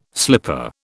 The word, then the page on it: slipper: British English